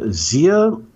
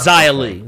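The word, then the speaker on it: Xia Li: Is it Zia or is it Zaya? – Zaya. – It's Zaya Lee.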